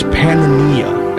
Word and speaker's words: Pannonia: Pan, it's Pannonia, as it's pronounced back then, and he grew up in a Roman Empire that was crumbling in slow motion.